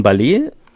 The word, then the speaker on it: Ballet: Das ist von Ballet, heißt die Band, und reimt sich auf Array. – Ja, weil das anders ausgesprochen ist, ja französisch ausgesprochen wird.